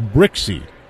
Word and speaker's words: Brzykcy: Zach Brixey's last name is spelled B-R-Z-Y-K-C-Y, pronounced Brixey.